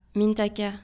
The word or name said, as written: mintaka